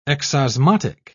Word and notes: exosmotic: British English

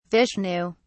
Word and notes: vishnu: British English

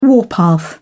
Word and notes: warpath: British English

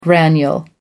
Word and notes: granule: American English

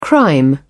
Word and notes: crime: British English

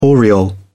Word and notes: aureole: British English

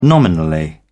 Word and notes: nominally: British English

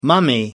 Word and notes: mummy: American English

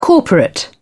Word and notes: corporate: British English

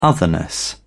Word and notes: otherness: British English

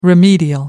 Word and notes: remedial: American English

